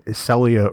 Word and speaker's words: Celia: I'm going to offer one minor correction, and that is we've been mispronouncing Celia Rose Gooding's name for at least a couple of episodes, yes, and that is the proper pronunciation.